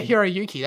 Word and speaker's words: Hiroyuki: Was it Hiroyuki? – Oh, yeah, Hiroyuki. That sounds right.